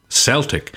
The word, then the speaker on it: Celtic: So here's the reason why I said Celtic, because there's a football team that plays in Scotland in the Scottish Premier League called Celtic, and that's how they pronounce their own name.